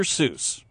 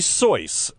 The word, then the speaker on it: Seuss: Everyone says Seuss, but according to even the doctor himself, it's supposed to be Seuss. – Oh, nobody pronounces it that way. – He said that, and eventually the doctor himself gave up. – First of all, it's a fake name. So we're pronouncing a fake name wrong. – It's supposed to be Soice. Soice.